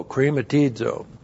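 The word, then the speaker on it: krematizo (χρηματίζω): Looks like that in the Greek, C-H-R. Long E, M, A, T, I, Z, long O, krematizo. Krematizo is used of God imparting an injunction.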